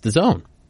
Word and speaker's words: DAZN: Personally, I think it should be pronounced DAZN, but it's DAZN.